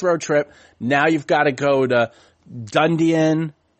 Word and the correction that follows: Dunedin: I always say it wrong. Whatever. – Dunedin. Yeah.